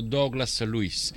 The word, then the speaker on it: Douglas Luiz: Mi diceva: adesso alla prima pausa ti mando un vocale e ti dico come lo devi pronunciare. E mi ha detto: devi pronunciarlo Douglas Luiz.